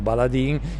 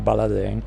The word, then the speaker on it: Baladin: Che tu, cioè, e non ti ho corretto, perché sì, mi piace, sì, che sia letto l'italiano, e anzi il 99% degli italiani chiamano le nostre birre, il nostro progetto Baladin, in realtà si pronuncierebbe Baladin.